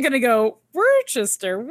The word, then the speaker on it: Worcester: But Nate says Worcester and pronounces it the Massachusetts way.